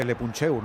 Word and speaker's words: Le Puncheur: Le leemos ahí no sé cómo se dice, Le Puncheur, no sé cómo se dice eso, Sergio. – No, lo has dicho bien, lo has dicho bien.